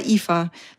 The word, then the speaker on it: Aoife: Mich fragt man in Irland immer, wie Eva mit IVA oder, weil es gibt ein gälisches Eva und das schreibt man mit A-O-I-F-E. – Na, A-O-I-F-E. Und das heißt, sagt man aber Eva, weil alles bis zum I bleibt stumm. Und das muss man erst einmal wissen. Oder der Name Neve, das heißt Licht und das schreibt man N-I-A-M-H.